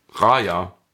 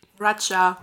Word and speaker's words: Raya: Wie spricht man R-A-Y-A aus? Raja. – Raja.